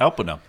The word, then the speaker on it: Alpena: Alpena, Alpena. I'm sorry if I'm mispronouncing that. – Alpena. – Yeah, it's spelled like Alp Enna.